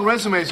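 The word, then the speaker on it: résumé: Oh, Rach, we're running low on resumes over here.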